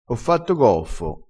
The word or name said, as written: goffo